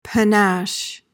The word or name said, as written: panache